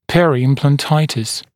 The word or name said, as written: peri-implantitis periimplantitis